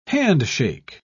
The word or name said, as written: handshake